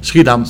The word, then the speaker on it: Schiedam: Schiedam. – Schiedam.